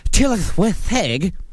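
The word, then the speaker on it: Tylwyth Teg: Various folklorist traditions refer to them euphemistically by names such as wee folk, good folk, people of peace, fair folk, which in Welsh is pronounced tillith with heg.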